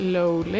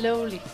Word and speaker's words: Lowly: Er det Lowly? Ja. Yeah. Lowly. – Ja, lige præcis. Som i low, og så ly.